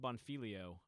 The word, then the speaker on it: Bonfiglio: Rob Bonfiglio, I think that's a silent G in his name. And if you're wondering how the hell you spell Bonfiglio, it's Rob Bonfiglio, B-O-N-F-I-G-L-I-O.